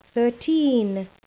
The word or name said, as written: thirteen